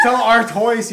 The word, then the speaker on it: Stella Artois: No, you pronounced that wrong. – Pepper. – It's whatever. – Stella Artois. – It's Stella Artois, you uncultured swine.